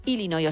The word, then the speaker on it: Illinois: Pero es Illinois, o sea, si tú lo pronuncias bien y tienes buena dicción, es Illinois.